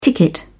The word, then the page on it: ticket: British English